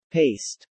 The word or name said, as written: paced